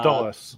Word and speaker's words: Dulles: Uh, and I always mispronounce the uh, it's uh, Dula- uh, Dulles.